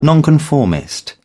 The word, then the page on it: nonconformist: British English